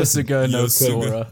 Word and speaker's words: Yosuga no Sora: Yosuga no Sora.